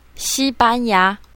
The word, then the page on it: xi1ban1ya2: mandarin